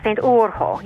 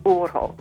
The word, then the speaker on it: Urho: Yeah, St. Urho. Yes, we kind of try to roll the R and pronounce the H. The Urho.